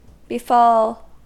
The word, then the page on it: befall: American English